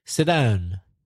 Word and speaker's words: sit down: sit down. And we don't bother to pronounce the T from sit. It kind of merges with the D. Sit down.